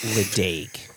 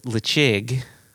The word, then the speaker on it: Ledaig: What it isn't, what it clearly is not, it's not Ledaig. And I know our wonderful friend David Sturck says Ledaig, and he's like, it's spelled Ledaig. I'm going to pronounce it Ledaig. God love him. So it's Lechig, and then Glenmurray.